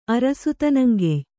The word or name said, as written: ಅರಸುತನಂಗೆಯ್